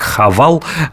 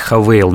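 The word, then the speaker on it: Haval: Марка такая пишется как Хавал. Они рекомендуют называть ее все-таки Havail.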